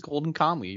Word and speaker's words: Golden Kamuy: It's called I'm probably gonna butcher the last word of it, but it's Golden Kamuy. – It is Golden Kamuy.